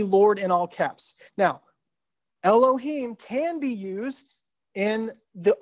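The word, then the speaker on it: Elohim (אלהים): And we pronounce it the way that we would pronounce it would be Elohim. You may have heard that term before. It's a Hebrew word pronounced Elohim when you see the word God.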